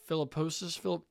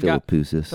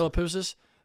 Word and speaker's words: Philippoussis: he's added Mark Philippoussis. I'm absolutely butchering his name, but he's added the Philippoussis. – Guy. Philippoussis.